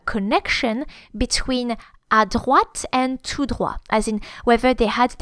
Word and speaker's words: tout droit: The word for straight ahead is tout droit. And they're both words are spelled with a final T, which is not pronounced because it's final. Tout, T-O-U-T, and then droit, D-R-O-I-T. Tout droit, straight ahead.